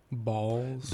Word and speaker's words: Bawls: How do you pronounce that? – Balls. – That is correct.